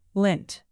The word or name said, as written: lint